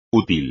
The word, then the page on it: útil: espagnol